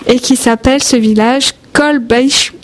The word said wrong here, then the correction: Kolbsheim: Ah non, voilà, j'ai raté mon coup. Je ne vais pas le refaire. – Kolbsheim. – Kolbsheim, qui s'écrit K-O-L-B-S-H-E-I-M, qui se trouve en Alsace, et qu'on va inviter nos amis auditeurs à aller aussi découvrir.